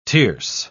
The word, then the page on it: tierce: British English